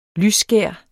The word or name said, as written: lysskær